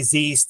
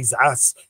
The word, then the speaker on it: Zeiss: So I have Zeiss brochures on the table and they go, oh, they'll say Zeiss, they'll say Zass, you know, they'll mispronounce it or I'll mispronounce it, whatever it is.